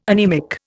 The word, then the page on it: anaemic: Indian